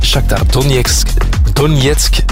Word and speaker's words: Shakhtar Donetsk: En ook PSV en Shakhtar Donetsk, dat is de juiste uitspraak, die speelden 1-1 gelijk.